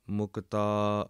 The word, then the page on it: mukta: Punjabi